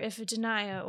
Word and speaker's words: Iphigenia: It'll be called How Iphigenia Met the Stars, or Iphigenia, or, you know, it just depends on who you ask how you say her name.